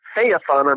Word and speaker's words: Phaethon: You make it so Phaethon, I'm teaching it by writing F-A-Y in big letters, then E, then T-H-O-N. Then you could actually pronounce it, right?